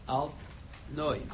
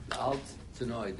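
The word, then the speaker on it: Altneu: So some say it's a German expression, the old new shul, Alt Neu. That's how they spell it. A-L-T hyphen N-E-U. Alt Tenoi, the old new shul.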